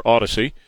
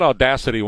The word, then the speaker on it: Audacy: But it's about this uh, George Soros deal of him taking over Audacy. Uh, is that how you say it? Audacy, right? Okay, because I said Audacity one time because I put a T in there, but it doesn't belong. It's Audacy.